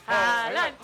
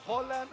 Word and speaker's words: Haaland: Es con O. – Y es rápido, es Erling Haaland. – Haaland. – Así es su nombre. – Pero es con doble O. – No, es con A, con doble A. Es con doble A, pero él dice, o sea, haz de cuenta que normalmente cuando hay torneos se graban cómo es mi nombre.